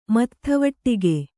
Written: ಮತ್ಥವಟ್ಟಿಗೆ

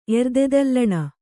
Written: ಎರ್ದೆದಲ್ಲಣ

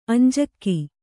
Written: ಅಂಜಿಕೆ

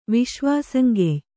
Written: ವಿಶ್ವಾಸಂಗೆಯ್

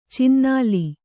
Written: ಚಿನ್ನಾಲಿ

